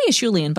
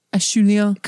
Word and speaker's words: Acheulean: Did I say that right? – I say Acheulean, but I've heard it both ways, so I don't know. – Acheulean. – Acheulean, because it's named after a site, Saint Acheul.